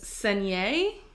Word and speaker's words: Saignée: Saignée? – Yeah. – S-A-I-G-N-E-E. And then one of the E's has an accent. – Saignée.